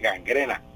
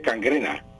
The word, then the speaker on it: gangrena: La palabra gangrena no, es el habla corriente y popular, pues la gente dice gangrena. Y, perdón, dice cangrena. Y la palabra correcta es gangrena.